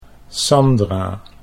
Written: Sandra

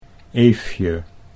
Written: Eefje

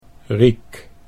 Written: Riek

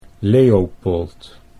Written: Leopold

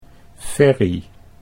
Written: Ferry

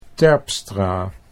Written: Terpstra

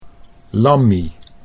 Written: Lammie

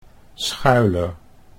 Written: Schuyler

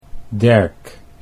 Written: Derk

